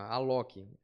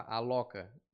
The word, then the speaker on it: Alok: E até tem gente que, porra, Alok, é A-L-O-K. Se você uhum. for falar ao pé da letra, fica Aloca, mas não é.